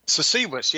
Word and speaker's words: Sasewis: Is a member of the Red Cedar Circle of Southwest Illinois that follows the CC Wace medicine. Is that how you say it? – Sasewis, yeah, you're closer than most people.